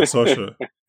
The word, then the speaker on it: Saoirse: Yeah, Saoirse.